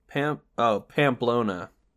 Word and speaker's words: Pamplona: That's how you say it, right? I feel like it's Pamplona. I'm going to look it up because I'm either super smart or I'm an asshole. No, I think that's right. Pamplona.